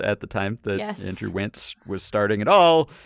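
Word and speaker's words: Wantz: Because the Angels and Phil Nevin, replacement for Joe Maddon, he started Andrew Wance, rhymes with pants.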